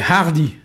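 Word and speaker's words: hardiz: C'est hardi. Hardi. On entend souvent ça. Hein? – Ah bah oui. – Alors ça s'écrit avec un Z à la fin, mais qu'on n'entend pas, mais qui sert à former, par exemple, hardizat, hein, devenir plus courageux, osé, hein, ou bien hardiiges aussi, hardiziges.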